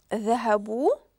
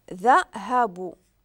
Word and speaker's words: ذهبوا: الافعال بزوج كانوا ذهبوا، وعندنا هنايا في النهايه هذيك الالف مع السكون اللي ما كيتنطقش. ذهبوا يعني ذهبوا مشاو، وقلت لكم هذه راه الجماعه ديال الغائبين، الجماعه ديال الرجال يعني مذكر ولكن غائب.